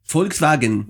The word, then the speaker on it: Volkswagen: Για, για πάλι το Volkswagen, γιατί μου άρεσε που έβαζε γερμανική προφορά. Volkswagen.